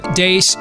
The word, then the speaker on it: Deace: Oh, you can like us on Facebook and follow us on Twitter at Steve Dace Show. Last name is spelled D-E-A-C-E.